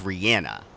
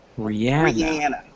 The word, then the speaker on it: Rihanna: Her name is actually pronounced Rihanna. – What? – Yep. – Rihanna. – Rihanna, not Rihanna. That's how she says it.